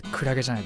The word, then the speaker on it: 海月: 海月と書いてクラゲじゃないですか。